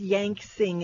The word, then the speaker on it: Yank Sing: All right, guys, I just got off the phone with Yank Sing. It's spelled Yanksing, as in sing.